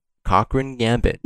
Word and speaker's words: Cochrane: So I go back and he's like, number 7, Cochrane Gambit. It's spelled C-O-C-H-R-A-N-E. But the way you say it, it sounds like cock ring gambit.